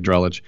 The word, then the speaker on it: Drellich: But anyway, Evan Drellick, who I finally know how to say his name, he said he'll take Drellich,